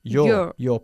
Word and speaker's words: you're: Bien, you are, la contracción se pronuncia como si fuese una sola sílaba. You're pointing at your right arm.